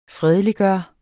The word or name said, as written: fredeliggøre